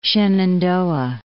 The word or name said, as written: Shenandoah